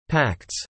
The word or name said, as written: pacts